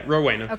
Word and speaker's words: Rowena: percent uh, so, Bonnie Elizabeth Parker was born on October 1st, 1910, to Emma and Charles Parker in Rowena, Texas. I think I'm saying that right, hopefully. – Yeah, Rowena.